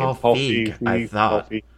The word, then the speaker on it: Feig: How do you say his name? – Paul Feig.